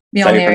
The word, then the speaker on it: Mjolnir: Mjolnir, yeah.